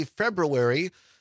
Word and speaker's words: February: Is it February, the way everybody says it? Or is it February with the R in it? I most Wisconsin say February. The R is silent. But I think grammatically speaking, February is the right way to say it.